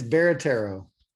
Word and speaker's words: Barattiero: It's Barattiero.